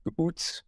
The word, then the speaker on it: Kuuts: Now, he smoked a Mayan cigar, all right, called Kuts, K-U-U-T-S, Kuts. I'm not sure if that's the right spelling in English, but that's how it's pronounced.